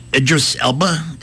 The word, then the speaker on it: Idris Elba: And Idris Elba, did I pronounce that correctly? – Yeah.